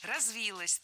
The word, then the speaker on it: развилось: Развилось тоже не лучший вариант. Более предпочтительным считается вариант с ударением на окончании. Развилось.